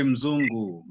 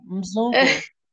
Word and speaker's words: mzungu: Here they say Mzungu. I am Mzungu. – You say Mzungu. – Mzungu. – Mzungu, that's how you say it.